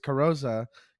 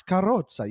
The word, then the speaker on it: Carosa: But like if you were my parents, they are mad at me for pronouncing it this way. They're like, it's Carosa. You have to have like a little bit of a bounce. You have to throw in a rolled R.